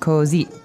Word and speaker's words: così: So remember, the accent is on the end of the word. Così.